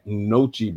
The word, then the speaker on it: gnocchi: Have you guys had Gnocchi before? That G is silent, if I'm not mistaken.